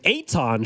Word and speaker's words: Eitan: The only reason why Akon is playing is because we were talking to a guest named Eitan Shander earlier in the week. And Drew pronounced his name a little bit funky and you said it's Eitan. I said like Akon, but Eitan.